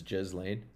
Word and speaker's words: Ghislaine: Is that how you say her name? Is it? It's Ghislaine.